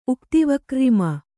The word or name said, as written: ಉಕ್ತಿವಕ್ರ್ರಿಮ